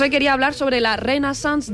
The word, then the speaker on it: Renaissance: Renaissance.